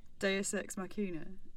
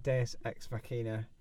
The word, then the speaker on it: Deus Ex Machina: How do you say it really? – Deus Ex Machina. – Okay. – I think. – I didn't know that. Deus Ex Machina. Yeah. And apologies to all the hundreds of students over the years. Who have heard me say that wrongly. – Well, maybe I'm saying it wrongly now.